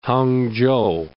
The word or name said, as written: Hangzhou